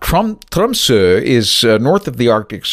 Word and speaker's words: Tromsø: And it kind of looks like Tromsø, but the O has a slash through it. And from what I understood at the time, the correct pronunciation of this Arctic village on the north shore of Norway is Tromsø.